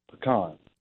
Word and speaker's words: pecan: So by the farmer's standard, it is pronounced pecan.